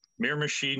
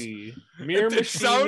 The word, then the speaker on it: Miramichi: Miramichi, New Brunswick. – Miramichi. I'm going to butcher that so many more times. – Miramichi, New Brunswick.